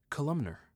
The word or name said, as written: Columnar